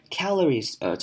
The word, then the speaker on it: calories: Calories, 中文是卡路里。那这边的 A 我们要念成短母音 at 的声音 calories，大家可以如果不太会念的声音，这个声音的话，可以把嘴巴练习张大一点 calories，把嘴巴稍微往旁边张大 calories，这样就可以念出 at 的声音了。